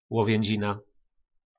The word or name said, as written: Lovjynzina